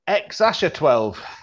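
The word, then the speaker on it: X Æ A-12: And you pronounce it, apparently, X-Asher-12.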